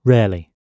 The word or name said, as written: rarely